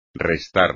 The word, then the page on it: restar: espagnol